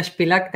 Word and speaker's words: Spilak: Yeah, my name is Simona Spilak. That's the correct pronunciation, but it's hard really to say it.